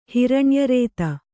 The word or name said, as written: ಹಿರಣ್ಯರೇತ